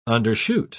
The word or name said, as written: undershoot